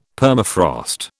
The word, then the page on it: Permafrost: British English